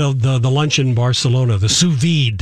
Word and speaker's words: sous vide: Sous vide. – Sous vide.